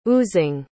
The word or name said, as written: oozing